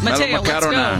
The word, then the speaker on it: melomakarona: How do you say it? – Melomakarona. – Melomakarona.